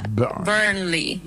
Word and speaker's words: Burnley: Se pronuncia Burnley. La primera Burnley parte suena como Burn. Con el acento ahí.